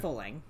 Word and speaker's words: fowling: So I was just gonna, it is 100% fulling. – Yeah. – So it's like bowling, but with an F.